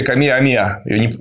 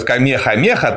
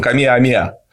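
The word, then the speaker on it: Kamehameha: Ну, короче, была такая королевская династия Камеа-Амеа, у нас неправильно называют Камеха-Меха, там хэ не читается, очень певучий, это сам полинезийский язык, он Камеа-Амеа.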